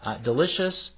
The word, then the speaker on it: del.icio.us: It's spelled del.icio.us, but pronounced Delicious.